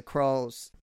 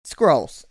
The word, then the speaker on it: Skrulls: Skrulls.